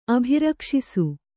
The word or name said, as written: ಅಭಿರಕ್ಷಿಸು